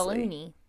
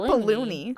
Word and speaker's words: baloney: All right, let's go ahead and since it's connected, move on to 191, which is people who spell it like B-A-L-O-N-E-Y. – Spell it like it should be spelled, honestly. – Baloney. – Baloney. Not balloony.